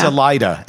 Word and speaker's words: Salida: Is it Selena or is it Salina? – Salida, S-A-L-I-D-A. – Really? See, I would have totally pronounced it the other way.